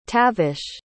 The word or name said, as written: tavish